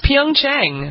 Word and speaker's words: Pyeongchang: Pyongyang, Wongyang, Bongyanghung. Something like that. Okay, go ahead. It's perfect. You pronounce it. – It's not hard. It's Pyeongchang.